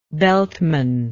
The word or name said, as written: beltman